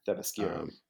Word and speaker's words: Themyscira: You know, I think Wonder Woman also, you know, coming from, how do you pronounce it? Thymasiri? I can't pronounce it. – Themyscira.